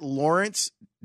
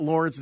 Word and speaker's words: Laurence: At Lawrence W. Holmes. L-A-U-R-E-N-C-E. Like the saint. My name is spelled like the saint. So, at Lawrence W.